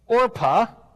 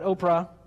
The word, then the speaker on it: Orpah: Orpah, not Oprah, you've got to say it right.